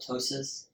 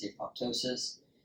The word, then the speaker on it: apoptosis: Some people don't like apoptosis said that way, so they call it atosis. Uh, the P is silent. I've always heard it as apoptosis.